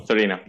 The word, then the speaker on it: Torino: Did I say that right? – Yeah. Cool. Torino. Yeah.